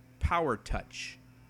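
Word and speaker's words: Pow R. Toc H.: So I call it Power Talk H. – I always read it in my mind as Power Talk H, but in reading up, I understand that some would pronounce it Power Touch.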